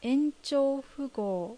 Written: Enchoufu

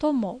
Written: Tomo

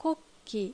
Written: Kokki

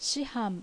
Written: Shihan

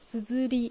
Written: Suzuri

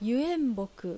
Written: Yuenboku

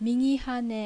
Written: Migihane

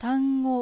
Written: Tango

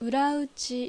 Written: Urauchi

